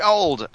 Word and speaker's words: Auld: Nick Auld, I hope I'm saying that right, Nick. Yeah. It's A-U-L-D. I always have a problem with that. Auld, yeah.